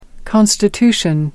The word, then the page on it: constitution: American English